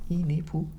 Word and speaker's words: Inepu: Inepu ist die, mit Betonung auf dem I, Inepu.